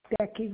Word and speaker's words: Becky: Becky Raskovich. – Becky, B-E-C-K-Y? – Yeah.